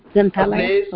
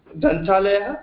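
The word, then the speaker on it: ग्रन्थालय: Library in Sanskrit, probably, you know, a Granthalaya. – Granthalaya, okay. – Granthalaya, yeah.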